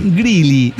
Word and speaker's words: Greeley: Se escribe G-R-E-E-L-E-Y. – Usted. – Greeley, vendría a ser Greeley. Está usted, cabrón, o sea...